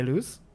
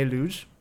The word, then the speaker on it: Luz: And then where the disappearance completely takes place is on vacation in Portugal to Praia de Luz. Did I pronounce so many things Praia wrong? – I think it's like Luge. – The Z is like Luge.